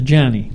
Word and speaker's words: Gianni: the Gianni is pronounced Johnny.